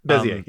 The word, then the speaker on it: Bezier: I think it's Bezier curves. Is that how you say it? – Bezier, yep.